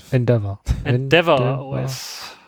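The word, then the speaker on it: EndeavourOS: Das wäre einmal Garuda und Endeavor OS. Ich kann es nicht aussprechen. – Endeavor OS. – Endeavor, okay. Endeavor OS.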